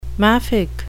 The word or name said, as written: maffick